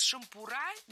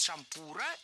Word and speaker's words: шампура: Действительно, с шампура неверно, а вот с шампура — это литературная норма. Во всех формах нашего слова мы сохраняем ударение на втором слоге.